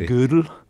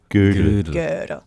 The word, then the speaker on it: Gödel: I hear it often pronounced, well, I don't speak German, but mathematicians often say it like the woman's piece of clothing, you know, from the old days. But something like Gödel.